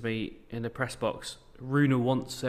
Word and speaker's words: Rune: Interesting, the Holger Rune, have to pronounce the E, we now know this, stuff.